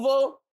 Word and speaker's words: ooVoo: Oh, Uvo? – Yeah.